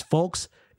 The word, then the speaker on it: folx: You can tell by the way that the voice says folks, it's spelled F O L X.